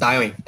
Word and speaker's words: Tywin: Twin, Twin, Twin, não sei como é que fala, Twin. É Twin mesmo? É, é Tywin, Tywin. Tywin, Tywin.